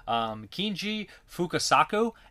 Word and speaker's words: Kenji Fukasaku: This director here, I'm going to make sure I say his name right, Kenji Fukasaku, and you know what's funny is he's actually one of the directors I probably cover the most on my channel.